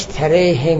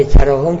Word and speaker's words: تراهم: حال من نمیدونم تراهمی که ایشون با یه نوشته درست یا نه، در حال اماله درسته، چون میدونید الف رو اماله میکنن، یعنی میلش میدن به یا، خوندنش تره هم میشه، به جای تراهم تره هم میگن، این میشمین اماله. ولی در نوشتن اماله نمیکنن، در تلفظ اماله میکنن.